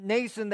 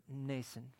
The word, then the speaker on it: Mnason: And so it's interesting, Mnason, the M is silent.